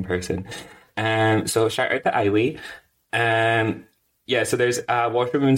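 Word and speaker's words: Islay: Is that how you pronounce it? – Well, some folk when they're talking about the whiskey they'll call it Isla, but I've got a pal and it's spelled like that, and she goes by Eile.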